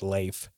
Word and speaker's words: Leif: I know Leaf Erickson was a Viking or explorer, but not, you know. – I don't get that reference. – He was an explorer Viking. Technically, I think you pronounce it Leif.